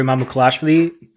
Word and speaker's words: Mamukelashvili: I'm not particularly a fan of Sandro Mamukelashvili at his price.